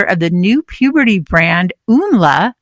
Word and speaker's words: Umla: I hope that I said that right. And I'm so glad to have you on. This is so exciting. Hello, Dr. Natterson. – Hello. You can call me Cara. And it's really nice to meet you. And thank you for having me. Yes, Umla. You said it right.